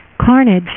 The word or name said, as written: Carnage